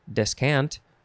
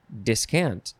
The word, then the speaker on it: descant: You can also emphasize the second syllable, so it's descant or discant. Des or dis again.